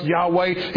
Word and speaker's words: Yahweh: Yahweh, YHWH, that's pronounced Yahweh.